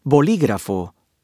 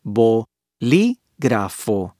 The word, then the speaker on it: bolígrafo: It means that the syllable containing the accented vowel is stressed when the word is pronounced, as in the word bolígrafo, bo-li-grafo, for example.